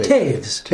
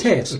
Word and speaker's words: Toews: Is that how do you say Taves. – It? Taves. – Toews works for me.